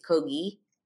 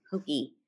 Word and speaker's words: Kogi: I would say Kogi, it's Kogi. Am I saying it wrong? – Hard G. – Kogi.